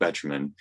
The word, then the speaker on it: Betjeman: David, my favorite song that David sang was The Permanent Way, where they have the poetry of John, and I apologize if I'm pronouncing this wrong, but Betjeman, as he says those lyrics, and there's a moment. Did I get it right, Geoff? – Yeah, absolutely. Yeah, yeah.